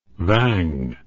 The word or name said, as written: vang